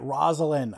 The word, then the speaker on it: Rosalind: Oh, that's how you say it. Rosalind.